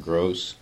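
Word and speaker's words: Groce: Mark Gross, M-A-R-K-G-R-O-C-E.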